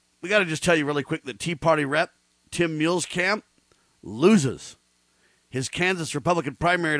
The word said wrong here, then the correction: Huelskamp: Did you say Muehlkamp? It's Huelskamp. – Huelskamp. Yeah, okay.